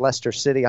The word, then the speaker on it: Leicester City: Leicester City, I hope I'm pronouncing that right, Kenny, in the EPL, but that's correct.